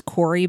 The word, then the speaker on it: Kouri: Anyway, a woman in Utah, I believe her name is Corey, but it's spelled K-O-U-R-I.